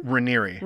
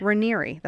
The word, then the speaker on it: Raniere: Raniere. – Raniere. That's weird. – It's R A N I E R E. – Okay. – And I've checked the pronunciation.